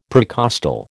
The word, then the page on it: precostal: American English